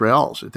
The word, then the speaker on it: real: Ex- uh, how do you pronounce the Brazilian currency? Real or real? – Real, my, yeah.